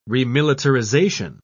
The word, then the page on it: remilitarization: British English